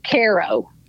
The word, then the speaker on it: Cairo: The town was called, spelled the same way, but we pronounced it Cairo.